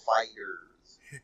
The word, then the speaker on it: FighterZ: So it's like spelled with a Z, so it's like Dragon Ball FighterZ.